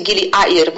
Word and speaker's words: Gili Air: Ogólnie wśród tych trzech wysp Gili R, właściwie mogłam powiedzieć, że to wymawia się Gili Air, bo w języku Bahasa właśnie tak się wymawia Air,